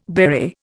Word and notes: beret: Indian

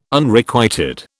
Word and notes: Unrequited: British English

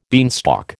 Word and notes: beanstalk: American English

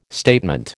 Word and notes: statement: American English

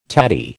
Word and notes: tatty: American English

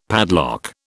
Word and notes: padlock: British English

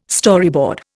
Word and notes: Storyboard: Indian

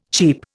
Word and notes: cheap: Indian

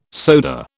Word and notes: soda: British English

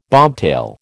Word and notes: bobtail: American English

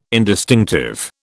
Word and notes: indistinctive: British English

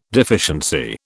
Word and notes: deficiency: British English

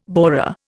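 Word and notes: bourrée: Indian

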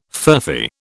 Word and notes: furphy: British English

